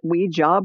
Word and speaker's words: Ouija: It's spelled O-U-I-J-A. And so some people maybe pronounce it Ouija board, but I always heard it was Ouija board. So that's how I'm saying it.